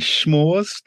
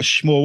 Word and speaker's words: s'more: So, I mean, I didn't know what a schmoor was, and you're all poking fun at me because I pronounced it schmoor. – But yes. – And you're like, no, how do you pronounce it? – S'more. – S'more. Okay, so I'm schmoor.